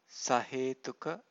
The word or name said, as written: sahetuka